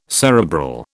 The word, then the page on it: cerebral: British English